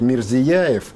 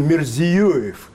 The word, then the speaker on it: Мирзиёев: У нас обычно его называют Мирзияев, что вызывает вопросы и даже напряжение в экспертном сообществе Центральной Азии. То есть ведущие эксперты говорят, почему путают фамилию? Он Мирзиёев.